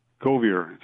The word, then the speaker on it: Govier: Govier. Straight English, no French affectation.